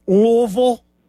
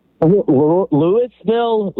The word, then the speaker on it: Louisville: I'm saying that right, Louisville? – Louisville? Louisville? Louisville. Huh. It really doesn't matter, man. If you're from here, though, you pretty much drop the I and the S part of it, though, in the Louis.